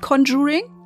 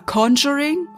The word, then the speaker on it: Conjuring: Und nein, es ist nicht Conjuring. Oder Conjuring, wir wollen es ja richtig aussprechen.